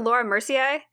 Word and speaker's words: Laura Mercier: Is it Laura Mercier? – Yes. – Yes.